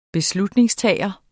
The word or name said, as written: beslutningstager